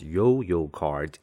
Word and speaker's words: 悠遊卡: 最后再介绍它的使用方式。所以我们就说 ,The Easy Card, the smart card used in the Taipei MRT system, is pronounced as Yo-Yo Card in Chinese.